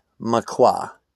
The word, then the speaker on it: Makwa: My name is Makwa, M-A-K-W-A, Makwa. I believe that's how you pronounce it.